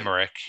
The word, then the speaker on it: Emmerich: Emmerich, or how do you say his name? – Emmerich, yeah.